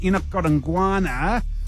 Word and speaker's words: Enoch Godongwana: If we gave points for pronunciation, you'd be nowhere. Are we in a forgiving mood? I think we're probably on balance going to have to give it to you. It is Enoch Godongwana.